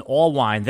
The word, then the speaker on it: Allwine: And I'm here with Jason Allwine.